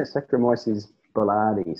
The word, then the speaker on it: Saccharomyces boulardii: Yeah, Saccharomyces boulardii.